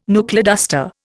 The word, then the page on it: knuckleduster: Indian